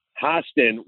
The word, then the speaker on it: Hostin: So according to Sunny Hostra or however her last Hostin. – Name is pronounced, Hostin,